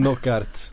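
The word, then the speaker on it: Knockaert: Jag, ty jag tycker, eh, du får rätta mig nu om jag uttalar hans namn fel, Knock Knockout. – Ja det där är ett svårt namn. – Det är ett väldigt svårt namn. Jag bara tänker det brukar vara uttalnings... No, knockart.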